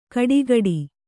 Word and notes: ಗಡಗಡೆ: gaḍagaḍe

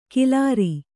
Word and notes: ಖಿಲಾರಿ: khilāri

